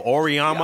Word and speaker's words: Auriemma: Amanaro. – Uh, yeah. Uh, Amamari. How you say his name? – I don't know. Auriemma. Yeah, Auriemma.